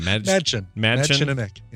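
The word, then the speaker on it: Mädchen Amick: Madchenemick.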